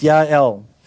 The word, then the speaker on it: Yael: That's how we would pronounce it in your Bibles, Yael,